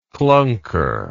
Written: klunker

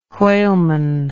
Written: whaleman

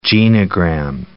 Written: genogram

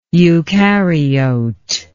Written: eucaryotic

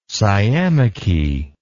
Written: sciamachy

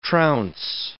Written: trounce

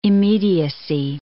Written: immediacy

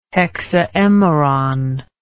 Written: hexaemeron

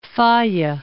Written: Falla

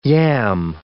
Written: yam

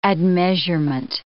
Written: admeasurement

